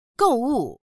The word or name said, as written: gou4wu4